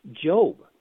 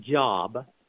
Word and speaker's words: Job: And the first reading is from Job. Now, you know, it's spelled J-O-B, and so it's not job. So it's, you know, it's not pronounced job. It's traditionally pronounced Job.